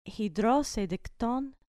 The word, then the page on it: hidrosein de chton: grec